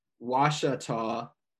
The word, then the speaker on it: Ouachita: This is straight from the mountains in, they have the phonetic pronunciation, Ouachita Mountains in Arkansas.